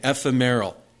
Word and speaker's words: ephemeral: Happiness is usually thought of in terms of comfort, True, genuine, deep-seated happiness, however, is something much more profound than the ephemeral. Anybody know what ephemeral means? Am I even pronouncing the word right? Ephemeral, thank you.